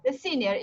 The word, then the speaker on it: The Sinner: Eu comecei, eu já terminei de assistir The Shiner, The Shiner, eu não sei como é que pronuncia esse negócio, não sei. Mas é, é, é policial. – The Sinner. Isso, me corrija, por favor.